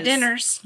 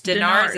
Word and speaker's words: dinars: Not dinners. – No. – Or not diners. That's not how it's spelled. It's dinars.